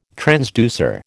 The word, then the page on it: transducer: American English